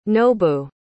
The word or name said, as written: nobu